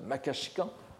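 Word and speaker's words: makashikan (摩訶止観): Nous n'avons pas manqué de rappeler combien ce traité, la somme de quiétude et de contemplation, le makashikan euh, en prononciation japonaise, Guan en chinois,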